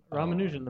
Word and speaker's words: Ramanujan: Is that the correct pronunciation of his name? – Ramanujan, the mathematician.